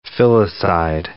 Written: filicide

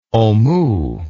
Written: omoo

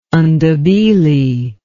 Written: ndebele

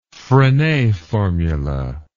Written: frenet formula

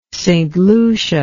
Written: St Lucia

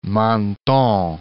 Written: Menton

